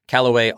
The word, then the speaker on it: Callaway: Callaway, all A's like the golf clubs.